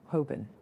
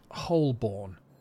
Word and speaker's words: Holborn: Yes. Well, no, it's pronounced Holborn. – Okay, I see it now. Yep, I definitely would have said Holborn.